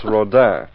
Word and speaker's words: Rodin: Axford, for your information, the name is pronounced Rodin.